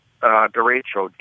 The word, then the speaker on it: derecho: Derecho, D-E-R-E-C-H-O, Spanish for, well, straight line.